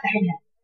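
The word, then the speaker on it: مجراها: مرققه يبقى تقولي هنا فيها اماله والراء مرققه وكيف نقراها؟ – مجريها.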